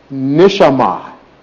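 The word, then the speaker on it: nishama (נשמה): And that Hebrew word right there is pronounced nishama.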